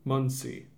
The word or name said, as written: Muncie